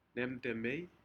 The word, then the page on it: nemtemei: Aneityum